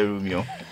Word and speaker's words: Rumion: You say it rhymes, okay? It resonates with room. So tell us about the Rumion.